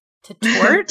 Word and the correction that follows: Tatort: So that TV show is called Tatort, which is basically just crime scene in German.